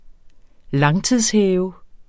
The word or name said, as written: langtidshæve